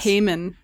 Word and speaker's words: Haman: Haman instead of Hammond. I keep thinking you're saying like Richard Hammond, and I'm like, what? It's Haman. I think it's Haman in the thing, because I just remember her saying it like that.